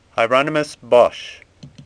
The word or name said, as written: Bosch